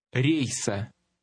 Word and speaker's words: рейса: Number 19 contains a combination of the stressed short E and the letter sounding like the end of the word gray. Try it. – Рейса.